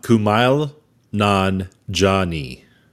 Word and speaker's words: Kumail Nanjiani: Kumail Nanjiani. Nanjiani. – Kumail Nanjiani. Kumali. Oh, Kumail? – Hold on. – I'm sorry. We're so sorry. We're a bunch Kumail, of. Hold on. Stop. Kumail Nanjiani.